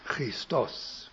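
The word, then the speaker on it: Christos (Χριστός): Wir haben im Deutschen die Formulierung aus dem Lateinischen mit dem U am Ende, in der letzten Silbe, Christus. Aber im Griechischen hat es dort ein O, Christos.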